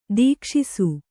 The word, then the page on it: ದೀಕ್ಷಿಸು: Kannada